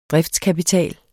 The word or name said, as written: DRIFTSKAPITAL